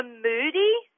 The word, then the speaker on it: Mudi: One's the Moody. It's spelled M-U-D-I, and they're a herding dog, so they belong in the same group as my border collies.